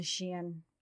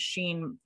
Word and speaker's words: Shein: Let's definitely bring up that you're wearing a Shein outfit and the fact that how upset I am that I've been pronouncing it Sheen as long as it's been around.